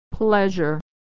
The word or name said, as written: pleasure